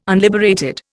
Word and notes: unliberated: Indian

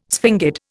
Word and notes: sphingid: Indian

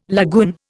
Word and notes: lagoon: Indian

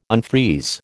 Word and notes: unfreeze: American English

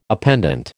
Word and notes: appendant: American English

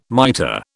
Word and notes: mitre: British English

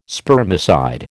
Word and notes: spermicide: American English

